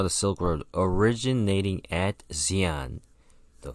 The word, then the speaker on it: Xi'an: Okay, it's spelled X-I-A-N. It's just pronounced Sin, Xi'an. The X is pronounced with an S.